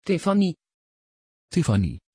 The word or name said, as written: Tiffany